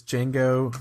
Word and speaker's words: Django: It was Django, The D is Silent, Unchained.